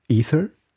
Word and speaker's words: Ether: Oder zumindest, oder Ether oder Ether, wie auch immer man das aussprechen will, geht dann so eine Währung auch verloren?